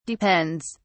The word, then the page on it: depends: British English